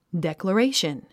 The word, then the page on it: declaration: dèkləréiʃən; デェコレェイション